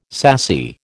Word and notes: sassy: American English

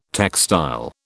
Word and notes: textile: British English

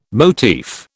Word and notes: motif: British English

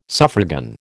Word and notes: suffragan: American English

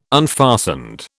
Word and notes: unfastened: British English

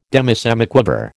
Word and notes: demisemiquaver: American English

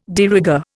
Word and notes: de rigueur: Indian

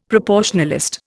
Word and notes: proportionalist: Indian

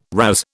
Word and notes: razz: British English

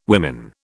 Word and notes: women: British English

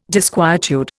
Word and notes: disquietude: Indian

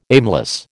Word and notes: aimless: American English

